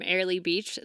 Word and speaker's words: Airlie Beach: Is it Airlie Beach? – Next Monday, our show will be coming to you from Airlie Beach, the Great Barrier Reef.